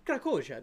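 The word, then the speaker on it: Krakozhia: Do I need to pronounce it like that? Krakoja. – Krakoja. That's what he says. They're like, where are you from? And he just goes, Krakoja.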